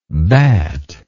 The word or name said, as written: batlike